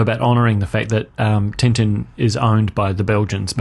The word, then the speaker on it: Tintin: he kept pronouncing it Tintin. Tintin, which is evidently how you pronounce it.